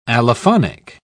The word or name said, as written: allophonic